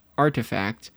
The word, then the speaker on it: RTFKT: As more people look towards expressing themselves in Web3 and Metaverse, RTFKT, pronounced Artifact, created a strong niche designing wearables for the digital and real world.